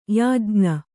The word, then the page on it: ಯಾಜ್ಞ: yājña; Kannada